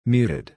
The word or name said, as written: muted